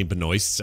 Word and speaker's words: Benoist: And I realize this doesn't have any reflection on her at all, but the name Benoist, and no offense to any Benoists out there, but that's a terrible name. You should go to the freaking downtown and get that fixed. – I just don't like it because it rhymes with moist.